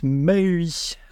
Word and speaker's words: MAUI: Et on va vous parler de .NET MAUI. Apparemment, il faut le prononcer Maui.